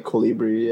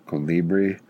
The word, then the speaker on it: Colibri: How do you say that? Calibri? – Yeah, probably Colibri, yeah. – Colibri.